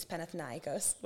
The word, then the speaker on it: Panathinaikos: Panathinaikos was how you pronounce it.